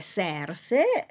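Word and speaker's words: Xerse: Nel Serse, che si scrive con la X, però è una X alla veneziana, se veneti, ex se veneti, no, se veneti, e quindi è Serse, e in quest'opera si racconta appunto le vicende intricate che caratterizzano le opere storiche.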